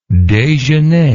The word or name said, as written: dejeuner